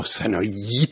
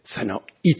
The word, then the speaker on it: سنایی: ما سنایی تلفظ نمیکنیم، سنایی تلفظ میکنیم.